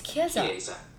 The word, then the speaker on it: Chiesa: And it's Chiesa. – Chiesa.